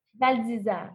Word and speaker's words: Val d'Isère: Val d'Isère, oui.